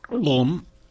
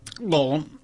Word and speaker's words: Gollum: Gollum. Gollum. – Mm-hmm. – That is how his name is said.